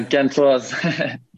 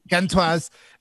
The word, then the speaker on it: Gantoise: You've played for Gantoy. I don't know how to pronounce it, actually. So you can please correct that. And that. – Yeah, Gantoise. – Gantoise.